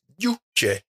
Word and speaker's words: Yujay: It's actually, if you want to say it properly, is Yujay and Harris.